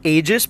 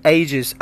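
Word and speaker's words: aegis: It is not ages, but ages. I spell it for you, A-E-G-I-S. A-E-G-I-S.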